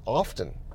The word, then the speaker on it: often: And drastic, dramatic things simply just, they don't happen often. Often, sorry, I've been working on the realization that the T in often is silent, and it's a difficult thing to work yourself out of that habit, by the way.